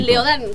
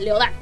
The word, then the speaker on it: Leodán: Leodán, con acento en la A, Leodán.